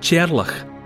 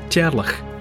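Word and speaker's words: Teàrlach: It's a mishearing of the Gaelic form of Charles, which is I apologise unreservedly for my, what's going to be my mispronunciation, but in Gaelic speakers' mouths, Charles is rendered into a name that sounds a bit like Cherlach.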